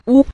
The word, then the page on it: wu: wu5